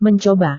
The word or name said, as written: mencoba